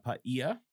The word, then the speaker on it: Paia: Because it's spelled P-A-I-A. – Yeah. – I'll tell you, I wouldn't have pronounced it Paia.